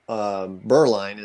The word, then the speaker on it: Bireline: So my last name is Bireline is how you pronounce it. I think Bireline. I should just take the E out and people would figure that out.